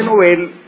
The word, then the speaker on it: Nobel: Se dice Nobel porque el premio, el señor Alfredo Nobel, en sueco se pronuncia Nobel, no Nobel, y los apellidos o los nombres pasan al español tal como suenan en su idioma original. Entonces, si en sueco es Nobel, pues en español también es lo mismo, Nobel.